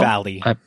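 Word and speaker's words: Vahle: There's going to be the president of Walt Disney World, Jeff Vale. Is that how you say his name? Jeff Vale? – Valley. – Valley. Oh, how interesting. Interesting spelling of it.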